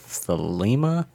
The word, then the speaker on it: Thelema: Crowley and Shumway looked for a house that would suit the purpose for the study of thelema, or I can't, I don't really know. Is it thelema or thelema? – I've always heard it pronounced dilemma. – Dilemma. Okay. – It's like dilemma, but.